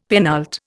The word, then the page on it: penult: Indian